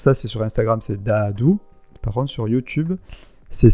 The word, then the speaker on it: Daadhoo: alors, pour le retrouver sur les réseaux, c'est important parce qu'en fait, c'est Dadou, mais ça s'écrit D-A-A-D-H-O-O. Ah oui. Donc c'est important de l'épeler parce que sinon c'est dur à trouver, c'est pas D-A-D-O-U.